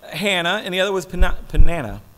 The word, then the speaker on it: Peninnah: And whenever the day came for Elkanah to sacrifice, he would give portions of the meat to his wife, Peninnah, that's how you say it, and to all her sons and daughters.